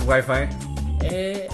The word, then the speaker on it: Wi-Fi: Para todos aquellos que no saben qué es WiMAX, es Internet Wi-Fi, si es que así se pronuncia. – Así es, Wi-Fi.